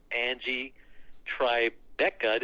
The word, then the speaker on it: Angie Tribeca: She recently guest starred in TBS's Angie Tribeca.